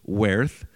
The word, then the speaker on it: weorth: I looked up how to pronounce it. W-E-O-R-T-H, werth. Which, of course, means worth. And shipe, S-C-I-P-E, shipe, which means something like shape or quality.